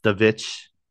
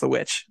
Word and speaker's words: The Witch: It's actually pronounced The Vitch. – It's actually stylized as The Vitch, but it's pronounced The Witch.